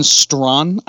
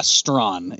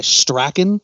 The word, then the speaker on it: Strachan: So, this is a well-known guy, Owen Strawn, I believe is actually how you say the name. – Is that the actual pronunciation? All these Strawn. – Years. – Sure. Yeah, Strawn is actually, but I think he also goes by Strachan and Strachan and however else you want to say the last name.